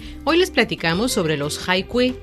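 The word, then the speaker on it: hai Kui (海归): Por coincidencia, en chino, la pronunciación hai Kui es homófona a la de un animal acuático, la tortuga marina.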